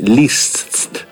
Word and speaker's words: Liszt: Tra l'altro ho fatto dei corsi per pronunciare bene il suo cognome, Liszt, perché ci sono quattro consonanti e una vocale, Liszt, adesso mi sono incasinato.